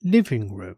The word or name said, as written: living room